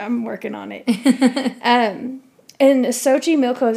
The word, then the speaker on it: Xochimilco: Xochimilco.